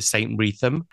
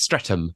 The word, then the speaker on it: Streatham: We always joke if you're posh, you call it St. Wreatham. but it's Streatham.